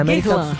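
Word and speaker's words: Guerlain: Primeira boutique de Guirlain, é isso? É assim que pronuncia, né? – Guirlain. – O que significa Guirlain.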